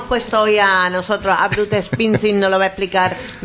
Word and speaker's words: Bruce Springsteen: Bruce Springsteen.